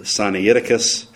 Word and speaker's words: Sinaiticus: He calls it Sinaiticus. I picked up the pronunciation, I think, from my teacher in seminary, Dr. Polhill, Sinaiticus.